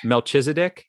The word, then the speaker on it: Melchizedek: How do we pronounce this? Dominion of Melchizedek. Melchizedek. Yeah, that's yes.